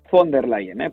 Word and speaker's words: von der Leyen: És von der Leyen, perquè a mi m'han dit, per què dius von der Leyen? Dic, perquè és com es pronuncia.